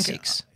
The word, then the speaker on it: Asics: Asics? – Yeah, Asics.